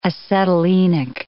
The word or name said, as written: acetylenic